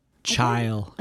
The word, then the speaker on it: Chile: I know that's like how people in America say it, but if he's like can speak Spanish, you think he would say Chile, right? – Yeah. Yeah. – How do you say it? – Chile. – The big chill. – The big chill. The big Santiago Chile. – Yeah. Chile. Chill, right? – Yeah, that's it.